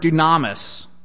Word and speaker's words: dunamis: It comes from the Greek word dunamis, which is spelled D-U-N-A-M-I-S. Dunamis. D-U-N-A-M-I-S.